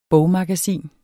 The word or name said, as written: bogmagasin